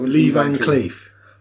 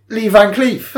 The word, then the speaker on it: Lee Van Cleef: Lee Van Cleef. Lee Van Cleef, is that right? – Yeah, that sounds about right. – What? How would you say it? – Lee Van Cleef.